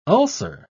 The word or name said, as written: ulcer